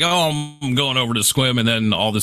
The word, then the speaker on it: Sequim: Especially because it's spelled S-E-Q-U-I-M, Sequim. Yeah. It's Squim.